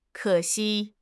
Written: ke3 xi1

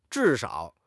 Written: zhi4 shao3

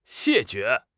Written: 谢绝

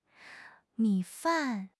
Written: mi3 fan4

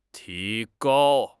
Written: ti2 gao1

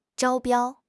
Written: 招标

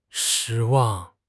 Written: shi1 wang4

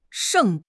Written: sheng4

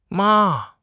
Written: ma5

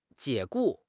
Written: 解雇

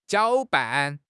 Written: 搅拌

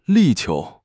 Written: 力求

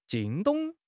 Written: 惊动

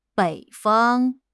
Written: bei3 fang1

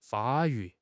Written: 发育

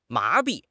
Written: 麻痹

